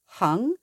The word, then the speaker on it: 行: So here we have hang, which is the alternative pronunciation to 行, but the same character.